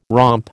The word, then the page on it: romp: American English